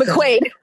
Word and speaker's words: McQuaid: She's the co-founder of Redemption House of the Bay Area with her partner, Lisa Diaz Quad. I think I always miss say that. Did I say it right, Quad? I think McQuaid. – So. McQuaid. Okay. Yeah, close. Okay. McQuaid. Lisa.